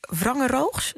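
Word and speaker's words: Wangeroogs: En dan heb jij, heb ik begrepen, een gedicht geschreven in het Wrangeroogs, zeg ik dat goed? – Ja, dat klopt.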